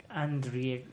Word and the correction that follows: Andrzej: no sé cómo se pronuncia, cabrón. Bueno, escritor ruso es. – Andrzej. – ¿Qué es? – Andrzej es ruso.